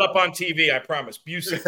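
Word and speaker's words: Bucyk: I have to correct your pronunciation. You're clearly not a hockey fan. That's okay. It's Busick. It's named after a former hockey player, Johnny Busick.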